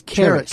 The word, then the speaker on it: Charis: Did I say it right? Cherish? – It's Charis. – Charis. – Charis is the word in Greek for grace.